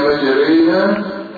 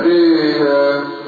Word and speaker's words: مجريها: مش ألف مسهلة لحفص غير الكلمة دي بس يكون الألف الممالة كلمة واحدة أيضا فقط هي مجريها مجريها شكرا كلمة واحدة فقط عند الحفص مجريها ريها هكذا يعني تنطقها أنت هكذا مجريها كلمة تكسر هنا وتكسر هنا يعني الألف دي تبقى والراء المفتوحة تبقى مكسورة ماشي